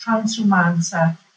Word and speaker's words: transumanza: And this is a very long-standing ancient tradition here in Italy, and it's called the transhumans, which in Italian is is pronounced Transumanza.